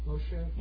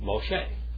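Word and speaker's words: Moshe (משה): How do you say Moses in Hebrew? – Moshe. – Moshe.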